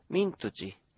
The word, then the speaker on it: ミントチ: ちょっとこれ難しいかもしれません。アイヌ語独特の発音です。 – はい、ミントチ。